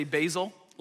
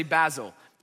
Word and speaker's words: Basil: Some people say Basil, like the leaf, okay? And then some say Basil. Basil sounds a little bit more academic, so I'm gonna roll with Basil today, okay?